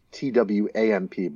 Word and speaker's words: TWAMP: It's actually spelled TWAMP, but people don't want to pronounce it the way you would. So they say TWAMP, yeah.